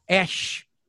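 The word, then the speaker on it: Æ: Aí o AE se pronuncia Ash. Então, vamos anotando aí. Então é X, Ash, né? E aí ele diz assim, é Ash como no inglês antigo.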